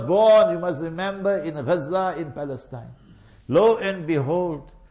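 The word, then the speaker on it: Gaza (غزة): You say Gaza, that's how the Westerners pronounce it. In Islamic terminology, Gaza.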